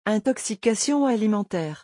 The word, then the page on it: intoxication alimentaire: French